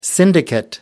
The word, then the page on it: syndicate: American English